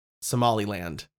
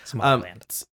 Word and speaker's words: Somaliland: Somaliland. That's how you pronounce it, yeah. – Yes, Somaliland.